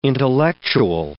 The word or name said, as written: intellectual